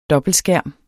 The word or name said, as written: dobbeltskærm